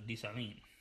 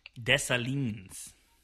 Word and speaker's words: Dessalines: How do you pronounce Dessalines? Dessalines. – Dessalines. – Yeah.